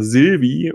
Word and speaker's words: Sylvie: Ähm, was ja schon mal äh, interessant ist, sondern äh, der Name wird ja letztendlich auch ausgesprochen Sylvie und das wurde ja schon,